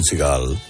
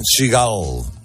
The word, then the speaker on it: Seagal: No es Seagal. – Claro. – Aquí es que se tiende a acentuar siempre, porque el hecho de que sea un apellido inglés en la primera sílaba, y no es así.